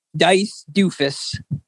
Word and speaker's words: Dice Doofus: Well, I know you were all talking about Dice World here, and my username, first of all, is Dice Doofus. – Can you spell that for us? – D-I-C-E, first, then space, then D-O-O-F-U-S.